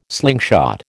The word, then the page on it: slingshot: American English